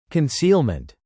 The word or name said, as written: concealment